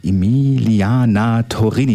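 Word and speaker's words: Emiliana Torrini: Ja, Emilia Torini hören wir jetzt. Ich hoffe, ich habe es richtig ausgesprochen. Emiliana Torini, jetzt genau.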